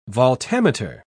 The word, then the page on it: voltameter: British English